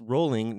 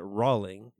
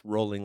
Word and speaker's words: Rowling: It's Rowling, not Rowling. Everyone says Rowling the way it's spelled. It's Rowling like bowling.